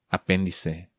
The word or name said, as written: Apendice